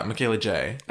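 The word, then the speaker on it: Michaela Jaé: Like, it has an accent on the E. – Oh, yeah. Michaela J.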